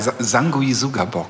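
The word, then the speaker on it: Sanguisugabogg: ja, Sangui Sugabok. also jetzt haben es alle gehört, so spricht man diese Band auch.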